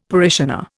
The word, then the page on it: parishioner: Indian